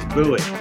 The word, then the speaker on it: Bowie: No, it's not Boo, wasn't I? How do I pronounce this? Come on in. – It's Bowie. Louis from Bowie.